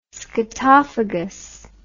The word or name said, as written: scatophagous